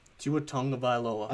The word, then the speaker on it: Tagovailoa: How you say it? – Tua Tagovailoa. – I've never heard it said.